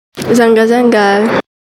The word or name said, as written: zenga